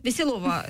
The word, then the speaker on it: Веселова: К нам пришла народная депутат Украины Наталья Веселова от партии, от фракции Веселова. – Партии. Веселова. Простите, у меня в русском языке ударения часто смещаются.